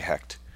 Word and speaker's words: Hecht: Oh, how would you pronounce that? H E C H T.